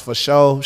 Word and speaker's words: fasho: They can find me F-A-S-H-O. So that's pronounced for show.